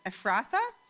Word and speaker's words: Ephrathah: But you, Bethlehem Ephrath," I say it wrong every time, "Ephrathah,